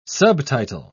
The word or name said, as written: subtitle